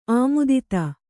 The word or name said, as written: ಆಮೋದಿತ